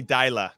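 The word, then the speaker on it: Deila: All right, we're going to pivot very quickly because I want to talk about this Ronnie Delia. Did I say it right? Delia. Ronnie Dyla. Thank you for that.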